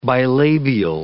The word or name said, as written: bilabial